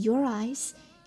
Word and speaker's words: your eyes: 好，来看一下发音技巧，我要提的呢就是 your eyes 这个短语了，你的眼睛有连读啊朋友们， your eyes 连读 your eyes。